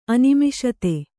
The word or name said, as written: ಅನಿಮಿಷತೆ